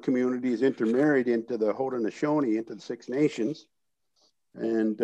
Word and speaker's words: Haudenosaunee: Haudenosaunee. – Haudenosaunee roots on your grandmother's side, on your...